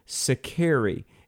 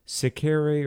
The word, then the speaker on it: Sicarii: It was written around 75 A.D., and Josephus mentions, and I'm probably not going to pronounce this correctly, a group called the Sicarii, S-I-C-A-R-I-I, Sicarii or Sicarii, I'm not sure.